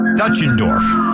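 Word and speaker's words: Deutschendorf: Actually, it's pronounced Dutchendorf.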